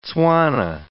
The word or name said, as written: Tswana